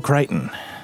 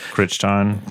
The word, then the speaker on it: Crichton: And welcome back to our discussion of The Andromeda Strain by Michael Crichton. Took me such a long time to be able to pronounce his name correctly. – Crichton. – Crichton, yes.